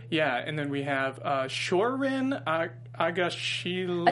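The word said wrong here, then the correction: Shohreh Aghdashloo: I think it's Shore Agadashlu. – Agadashlu, okay.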